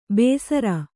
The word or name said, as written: ಬೇಸರ